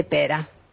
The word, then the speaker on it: Pere: que se escribe Pere el nombre, y acá Ulanowski me dijo, no, es catalán, así que se dice Pera.